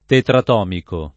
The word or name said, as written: tetratomico